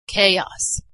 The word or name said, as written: chaos